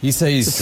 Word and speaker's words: Isaias: Did I say that right? Isaias? – Isaias. It is a tough one.